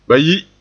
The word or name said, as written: bayi